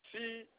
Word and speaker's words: Cheryl: My name is Cheryl. – Cheryl? – Yes, sir. – How you spell it? – It's Cheryl.